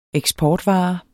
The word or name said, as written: eksportvare